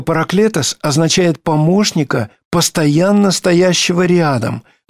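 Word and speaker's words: параклетос (παράκλητος): Слово «утешитель» по-гречески произносится «параклетос».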